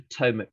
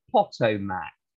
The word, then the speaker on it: Potomac: Did not Professor Langley's flying machine go to the bottom of the Potomac River? If this gets read out in England, the British always say Potomac, and all the Americans in the meeting laugh. I think it's pronounced Potomac.